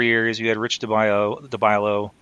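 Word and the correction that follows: Dalbello: I'm saying his name wrong, probably. – Del Bello.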